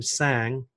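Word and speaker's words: Tsang: The other way, which is easier, is go to LinkedIn and type in my name, Allan, A-L-L-A-N. Last name Sang, it's like a silent T, T-S-A-N-G.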